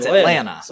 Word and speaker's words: Atlanta: Atlanta. You don't... Pronounce the T. – It's Atlanta. – This is not how English works.